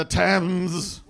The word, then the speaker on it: Thames: I was down by the Thames. That's how we're supposed to pronounce it, right? – Yeah. – All right. And so here's my bright spot. – Were you going to say Thames? – Thames.